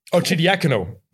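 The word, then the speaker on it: Arcidiacono: Hoe spreek je dat weer uit? Archie Diacono, zeker? Archie Diacono. Zo is het.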